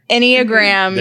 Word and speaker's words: Enneagram: Say it right. – Enneagram test. There we go.